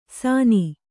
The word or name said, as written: ಸಾನಿ